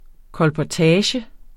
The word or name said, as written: kolportage